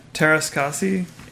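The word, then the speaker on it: Teräs Käsi: So in 97, we also got Masters of, how do you pronounce that? – Taras Kasi.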